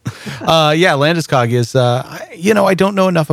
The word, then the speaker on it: Landeskog: What do you think about is it pronounced Landeskog? – I believe it's Landeskog.